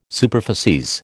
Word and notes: superficies: American English

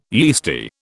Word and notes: yeasty: British English

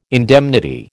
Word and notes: indemnity: American English

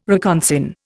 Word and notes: reconsign: Indian

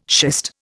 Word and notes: schist: Indian

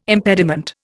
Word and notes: impediment: Indian

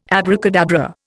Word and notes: abracadabra: Indian

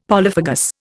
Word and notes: polyphagous: Indian

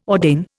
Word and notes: ordain: Indian

